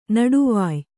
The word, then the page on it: ನಡುವಾಯಿ: Kannada